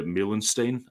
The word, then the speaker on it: Meulensteen: but bringing in Mike Phelan and Rene Meulensteen, I think is how you pronounce his name. I've never had to say that before. – Great pronunciation. That was on the money.